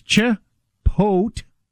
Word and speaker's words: Chipotle: So it's Chipotle. That's the emphasis is on pote.